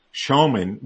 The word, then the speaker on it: Schoeman: It's Roy, R-O-Y, Schoeman, which is spelled S-C-H-O-E-M-A-N.